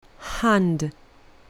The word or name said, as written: hand